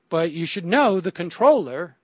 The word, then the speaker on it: comptroller: And it appears that comptroller is essentially controller and is in fact pronounced controller. She writes, despite the archaic spelling, the word is correctly pronounced controller. – I don't know who straight you wrong. – You did. The pronunciation as controller is unquestionably correct and is usually preferred. The comptroller pronunciation is increasingly common and is variously regarded as an error or as an acceptable new pronunciation.